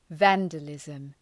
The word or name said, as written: vandalism